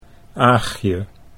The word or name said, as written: Aagje